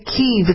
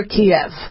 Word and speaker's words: Kyiv: Now remember, we're calling it Kyiv. It's no longer Kiev. Kiev was a Soviet-era name for the capital of Ukraine, and Ukrainians since the 90s have been pronouncing it Kyiv because it was founded by Prince Kyiv. So we don't use Kiev anymore.